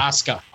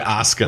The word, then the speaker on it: Asuka: Bayley versus, gosh, I can never say her name right. – Asuka. – Thank you, Asuka.